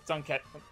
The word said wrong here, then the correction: Zantetsuken: Whatever it was called. – Zantetsuken. – Zantetsuken, thank you.